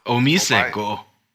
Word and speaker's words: OmiseGO: I've been seeing how do you pronounce OMG? Omisego? – Omisego.